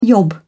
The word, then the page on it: yob: British English